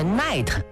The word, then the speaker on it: night: 来，跟我学一个语言小常识啊，英文里这个 night 晚上，里边这个 G 和 H 是不发音的。